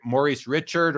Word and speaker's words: Maurice Richard: A lot of people, Josh, I notice, who aren't hockey fanatics like we are, pronounce it, you know, Maurice Richard, or they don't know how to say it. It is just for anyone who's watching. If you're not a hockey person, it is Maurice Richard, and his brother is Henri Richard.